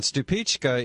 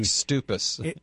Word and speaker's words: Stupice: And Stupichka is spelled not like it sounds, because it's spelled S-T-U-P-I-C-E. – What is the origin? – It looks like stupus.